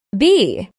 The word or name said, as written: bee